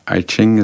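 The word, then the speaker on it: Ai Qing: De vader van Ai Weiwei, Ai King Ching, hoe moet je het uitspreken? – Dus Ai Ching is de vader van Ai Weiwei.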